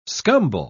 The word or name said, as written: scumble